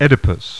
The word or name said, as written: Oedipus